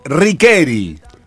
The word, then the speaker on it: Riccheri: La pronunciación correcta de la autopista, que se escribe R-I-C-C-H-E-R-I, es Riqueri. No es Riqueri.